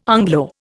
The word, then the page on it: Anglo: Indian